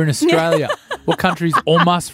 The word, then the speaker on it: hummus: That's how you meant to say omus, is how you pronounce it properly. I've got a great story about omus, but I think this break will go for too long. – Stop saying omus. – It's how you say it. It's a real way of saying it. Hummus is the Australianized version. It's omus.